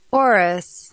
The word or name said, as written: Oris